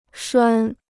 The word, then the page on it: 拴: shuān